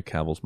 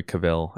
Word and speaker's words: Cavill: or Henry Cavill. I forget which one it is. I say it wrong. – Henry Cavill. – Is it Cavill? – I'm pretty. I don't think anyone calls him Cavill.